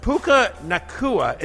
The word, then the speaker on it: Puka Nacua: Puka Nacua, is that how I say his name, Connor? – Yes.